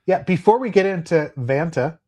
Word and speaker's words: Vanta: Is it Vanta or Vonta? Or? – It's Vanta.